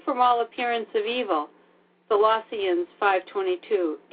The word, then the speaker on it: Colossians: Colossians, is that how you pronounce Colossians.